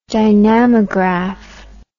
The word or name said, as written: dynamograph